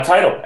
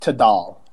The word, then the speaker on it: Tidal: Because right now this music video is exclusively on Tidal, and it premiered there, and therefore wasn't fighting for Vevo views. – It's actually pronounced Tidal. – Oh, I'm sorry. It's Tidal. My mistake.